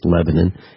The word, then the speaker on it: Lebanon: We pronounce it Lebanon. It's spelled Lebanon.